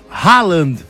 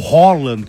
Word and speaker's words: Haaland: Então podemos aí, caso o Brasil passe, ter Haaland, ou Holland, como gostam os outros, Holland, é Haaland.